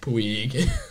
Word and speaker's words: Puig: They have Yasiel Puig. – Yeah. – Did I say that correctly that time? – Yeah, you're straight away from Puig. – Puig.